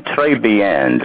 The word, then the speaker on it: très bien: Bon appetit and très bien. Did I say it right? – You can roll the R in the back of your neck a little bit more.